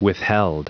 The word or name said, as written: withheld